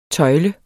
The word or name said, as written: tøjle